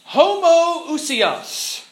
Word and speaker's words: homoousios: And they used this long Greek word, homoousios, to say that Jesus was of the same substance as the Father.